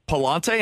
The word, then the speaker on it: Pallante: and that is Andre Pallante. Am I pronouncing that correctly there? – That's right.